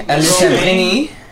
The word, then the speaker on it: Alessandrini: Say his name right. – His name Romain Alessandrini.